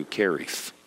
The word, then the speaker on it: Cherith: That's how you pronounce it in Hebrew, Cherith.